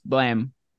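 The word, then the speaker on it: Blame!: According to Reddit, it's pronounced Blam.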